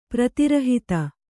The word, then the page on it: ಪ್ರತಿರಹಿತ: Kannada